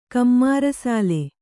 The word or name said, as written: ಕಮ್ಮಾರಸಾಲೆ